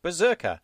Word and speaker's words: BRZRKR: In meanwhile, this week, Boom Studios is releasing a comic book written by Keanu Reeves with Matt Kent called BRZRKR, which I believe would be pronounced Berserker, which will be illustrated by Alessandro Vitti and Bill Crabtree.